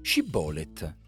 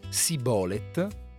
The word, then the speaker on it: Shibboleth: Shibbolet. Shibbolet significava torrente. Gli Efraimiti, nella loro lingua, non conoscevano il suono sh, come il principio di sciarpa, e quindi, con la loro cattiva pronuncia, pensiamola come Sibolet, rendevano subito palese di non essere galaaditi e venivano uccisi.